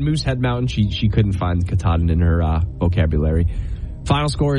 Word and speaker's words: Katahdin: Mount Katahdin. How do you say it? – Mount Katahdin. You said it. You said it right, but it's because you questioned it.